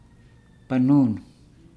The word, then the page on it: banunu: Nukuoro